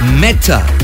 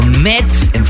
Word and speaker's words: Mette: Ella es la artista Meta. Se pronunciaría en inglés o Met en francés o Mete en español. Se escribe M-E-T-T-E con esto que se titula Mama's Eyes.